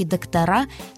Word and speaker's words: доктора: Говорить нужно «доктора», «профессора» и «директора». Других вариантов ударения в этих словах быть не может.